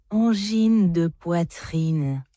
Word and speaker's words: Angine de Poitrine: Nu skal I lige høre, hvordan den her insisterer på, at det skal udtales. – Angine de poitrine.